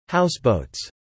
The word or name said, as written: houseboats